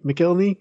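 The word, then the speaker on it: McElhinney: I would say, Cole Sharmis, Ian McKinney, if I'm saying that right, McKelney. – Yes, that's right.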